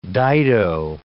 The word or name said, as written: dido